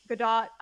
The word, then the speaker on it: Gadot: Gal Gadot, Gadot. I never know how to say her name. – I think right. – You do say the T, oddly enough.